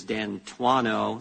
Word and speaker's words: D'Antuono: Just for the record, the pronunciation of the former Assistant Director in charge of the Washington field office is D'Antuono, something that ranking member might have known if he'd actually shown up at the deposition like I did.